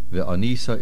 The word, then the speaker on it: ve'onisa: Ve'onisa is mil'el, the main accent on the nun.